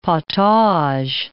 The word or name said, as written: potage